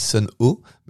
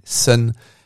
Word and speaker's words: Sunn O))): Il dit, bah, la majorité des gens disent Sun O, mais en fait c'est Sun.